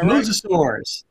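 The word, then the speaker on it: mosasaur: Was it mosasaurs? Did I pronounce that Mosasaurs. – Right? – Mosasaurs. Think of your favorite biblical patriarch. They are the mosasaurs, and they're marine reptiles.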